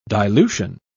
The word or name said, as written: dilution